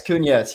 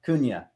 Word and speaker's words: Cunha: Yeah, it's Cunha. Yeah, so it's silent. I always tell people silent H out of Y. Cunha. It's Portuguese, man.